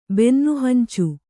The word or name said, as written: ಬೆನ್ನುಹಂಚು